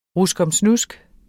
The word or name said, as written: ruskomsnusk